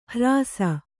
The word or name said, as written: ಹ್ರಾಸ